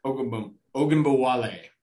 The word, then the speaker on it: Ogunbowale: Oh, how do you pronounce his last name? Dare Ogunbowale. – Ogunbowale. – Okay, I got close enough on that one.